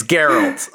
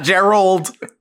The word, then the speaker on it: Geralt: It's Geralt. All right, Geralt of Rivia. – Yes. It's not Geralt. It's not Gerald. It's Geralt. – Garrett. – The hard G.